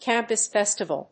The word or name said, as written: campus festival